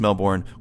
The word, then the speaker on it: Melbourne: It's pronounced Melbourne, by the way. Get a mention. I think we pronounce it all kinds of stupid ways. – Okay. – But yeah, Melbourne's the way that you're supposed to say it.